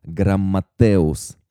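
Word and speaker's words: грамматеус (γραμματεύς): Но мне гораздо больше нравится, как в древнегреческом переводе Библии Септуагинте книжник произносится как «грамматеус».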